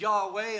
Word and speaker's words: Yahweh: And not only that, also in there, there was an extra biblical reference to the Israel God, the Israelite God, who we pronounce as Yahweh and some call Jehovah.